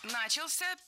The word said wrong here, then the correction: начался: первая ошибка. В этом глаголе ударным должен быть последний слог, возвратная часть «ся», которая, кстати, часто находится под ударением. Начался.